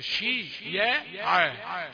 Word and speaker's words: شيعا: فتنطق الكلمة ظلما شيعا شيعا كأنها كأن الياء حرف مد نقول شي يا عا شي يا عا وجعل أهلها